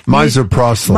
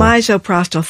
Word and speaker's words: Misoprostol: Misoprostol. – Misoprostol.